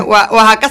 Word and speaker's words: Oaxaca: Oaxaca se escribe con X.